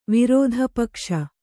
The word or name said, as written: ವಿರೋಧಪಕ್ಷ